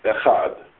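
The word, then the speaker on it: Echad (אחד): Echad. You do the first syllable quickly and extend out Echad.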